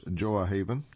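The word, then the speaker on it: Gjoa Haven: In late July, I met Tom, Jacob, and the other members of the search team in Goahaven, pronounced Joahaven, the only settlement on King William Island.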